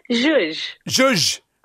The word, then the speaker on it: zhuzh: I actually went online and looked up the pronunciation of that word, T-S-Z-U-J, and how do you pronounce it? – Zhuzh.